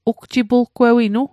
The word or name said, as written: ugjipulgewinu